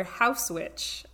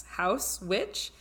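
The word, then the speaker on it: Haus Witch: I want to recommend the excellent store House Witch, that's spelled H-A-U-S, House Witch, in Salem, Massachusetts.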